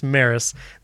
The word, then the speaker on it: Meross: It's spelled M-E-R-O-S-S, but it's pronounced Maris.